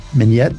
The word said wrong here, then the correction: Mnet: VUCA? – The VUCA Awards, the Mnet VUCA Awards.